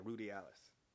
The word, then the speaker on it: ruderalis: Rud, rudi, yeah, ruderalis. Rud, ruderalis is the best. Is, is that's ruderalis? That's how you pronounce that. Sorry about that.